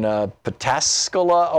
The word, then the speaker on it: Pataskala: Wendy in Pataskala, Ohio.